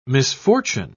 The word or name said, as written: misfortune